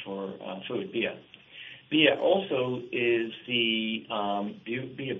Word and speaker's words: Bia: And is Bia the name of the company, B-I-A, pronounced Bia, is it short for something or what does it mean? – It's the Irish word for food.